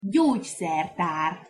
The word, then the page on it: gyogyszertar: Hungarian